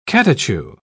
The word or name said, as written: catechu